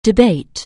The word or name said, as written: debate